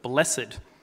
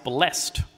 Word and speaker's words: blessed: Now, a fun fact, it's pronounced blessed when it's a verb and blessed when it's an adjective. And so clearly the first thing I'm telling you is that blessed here is an adjective.